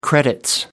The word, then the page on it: credits: British English